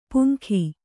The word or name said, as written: ಪುಂಗಿ